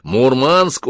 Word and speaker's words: Мурманск: Дав офицеру прикурить, сказал весело, — Мурманск уже скоро! Он сказал это на местном наречии, делая ударение на втором слоге.